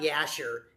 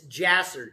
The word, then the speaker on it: Jasher: The word Jasher, that's how you pronounce that word, by the way. Not Yasher, it's Jasher, J-J-J, Jasher, Jasher.